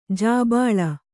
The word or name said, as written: ಜಿಬಿಲಿ